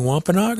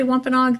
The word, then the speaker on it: Wampanoag: If you want to say Wampanoag, that's fine. Wampanoag would probably be the preference for most people in the community. – Wampanoag.